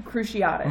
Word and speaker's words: Cruciatus: It's Cruciatus mm-hmm.